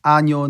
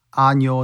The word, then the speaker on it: año: Feliz Año Nuevo. Feliz Año Nuevo. Prospero Año a ti. Prospero Año a ti. When you say año, it's not año, like American, it's año.